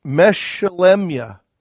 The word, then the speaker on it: Meshelemya: So when we see Christ in someone like Meshelemya, it would be how you pronounce his name in Hebrew.